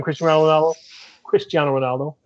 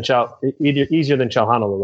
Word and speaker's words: Cristiano Ronaldo: I better get that pronunciation right. Easier than Chalhanolo, let's say this. Maybe not as easy for the ESPN guys, right, Richard? – No, they're going to have fun with that. Gosh, I mean, if it's anything like the friendly against Tottenham, that's going to be... Chalalulu was one. Chanalulu. I was waiting for Chugga Choo Choo at some point. It was just butchered just so badly.